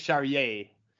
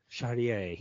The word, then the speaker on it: Charrier: Charrier is the last pirate, or is it Charrier? Am I saying it too fancy? – I think you're saying it right. He is actually French, so I think it's Charrier.